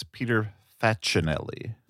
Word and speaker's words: Facinelli: As long as we're correcting name pronunciations, I believe it's Peter Facinelli.